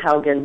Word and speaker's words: Haugen: It's actually Marilyn Haugen, but you were very close. – Haugen is the German pronunciation. – That is correct. That is right. – A-U is always ow, ow, ow.